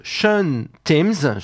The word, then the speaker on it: Sean Timms: On a certes craqué pour Southern Empire, le nouveau projet de Sean Timms, je prononce volontairement à l'anglaise, Sean si vous préférez Timms, et ce monsieur il officiait avant dans Unitopia.